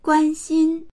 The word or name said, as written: guan1xin1